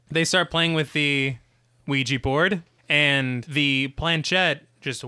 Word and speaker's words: Ouija: One thing I did, I always feel weird whenever I pronounce you know, I read that it was named after yes in French and yes in German, so it should be pronounced Ouija. – Yeah. – But nobody fucking does that. – No. – On the planet Earth. – Ouija is fine. – Ouija, it's like a squeegee.